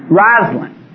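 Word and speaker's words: Rosalynn: Is it Rosalind? Am I saying the name right? Sounds wrong to me. Rosalind. Good.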